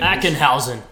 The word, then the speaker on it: Ackenhausen: Ackenhausen.